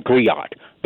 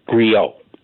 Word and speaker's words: griot: You kept saying griot. The word is pronounced griot.